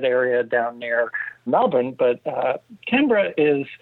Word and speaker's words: Canberra: Looks like it should be pronounced Canberra, but everyone there pronounces it Canberra.